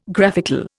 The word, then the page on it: graphical: Indian